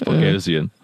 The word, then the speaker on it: Boghossian: Let's not get that name wrong. It's Boghossian.